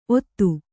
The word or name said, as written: ಒತ್ತು